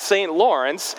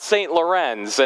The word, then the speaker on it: St. Lawrence: I learned that St. Lawrence is pronounced just like that, St. Lawrence, and not St. Lorenz, and I am eternally grateful that somebody pointed that out before I began to preach a sermon.